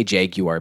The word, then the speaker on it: jaguar: I say jaguar because that's how they say it in the British car commercials ad.